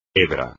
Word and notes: hebra: espagnol

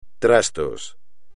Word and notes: trastos: espagnol

